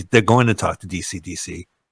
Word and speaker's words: Deacy: His last name is spelled D-E-A-C-Y. – D-E-A-C-Y. – DC. – DC. E-A is E sound, we were told.